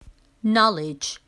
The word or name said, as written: knowledge